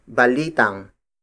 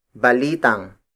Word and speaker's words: balitang: For larger tracts of land, they used balitang. Balitang. Balitang. Balitang is spelled as B-A-L-I-T-A-N-G.